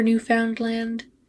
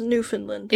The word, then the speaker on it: Newfoundland: Yes, it's pronounced Newfoundland. – It is? – Yes. – I've been saying it wrong this whole time and you didn't tell me.